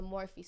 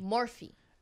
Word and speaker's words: Morphe: It's spelled like morph, pronounced Morphe. – That's so weird. – M O R. P-H-E. Is that Morph? – Oh, Morph is without the E at the end.